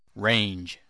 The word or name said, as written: range